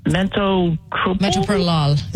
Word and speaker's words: metoprolol: The other medicine they put me on, I'm trying to remember how to pronounce it, metoprolol.